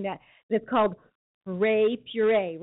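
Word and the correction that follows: Hooray Puree: right? Did I say it right? I love that. It's too cute of a name. Is it really that name? Hooray, Puree? – Yep, Hooray, Puree.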